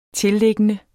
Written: tilliggende

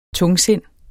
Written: TUNGSINDET

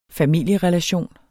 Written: familierelation

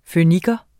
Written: føniker

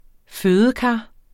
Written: fødekar

